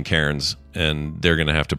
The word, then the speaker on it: Cairns: I know you're saying it's pronounced Cairns. Take it as an Aussie, Cairns. That's how you pronounce it.